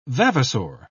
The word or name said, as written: vavasor